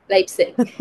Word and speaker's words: Leipzig: It's been really lovely to, and also to adopt lipstick. Am I pronouncing it right? – Leipzig.